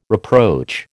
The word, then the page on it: reproach: American English